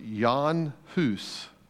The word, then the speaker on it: Jan Hus: As you look at his name in his native tongue, it would be pronounced Jan Hus. In English, we have transliterated it to become John Huss.